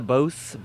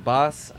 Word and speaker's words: Boss: Oh yeah, it's a Bose. Boss.